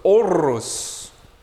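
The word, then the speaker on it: Horus: Oros, even in Hebrew, has a word for light, spelled with a he, vav, resh, but really, it's a silent H. So it's Oros.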